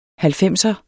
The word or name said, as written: halvfemser